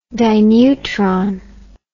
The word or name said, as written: dineutron